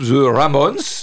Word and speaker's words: The Ramones: qui est une chanson du groupe The Ramones. Je ne sais pas si ça se prononce comme ça. Oui, oui.